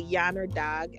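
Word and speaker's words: Yanardağ: So one of the famous sites in Azerbaijan is known for is the Yanardag.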